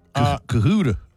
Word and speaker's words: Cohutta: Cohutta. – It starts with a C-O-H-U-T-T-A.